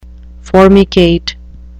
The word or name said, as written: formicate